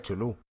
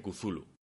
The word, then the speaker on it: Cthulhu: Cuando pues no se dice Chulú. Ya, se dice Cthulhu.